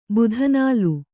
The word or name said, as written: ಬುಧನಾಲು